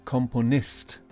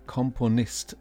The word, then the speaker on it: Komponist: We don't need to worry about the pronunciation of Milli Vanilli, but Komponist, Komponist, the stress is on Nist, and Richard, Richard Strauss.